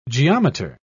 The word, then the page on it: geometer: British English